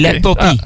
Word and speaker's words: Les Papiers: De S spreek je niet uit op dat moment. Okay. Les Papiers. Ah. Okay. Les Papiers moet je eigenlijk zeggen. Want de S die spreken.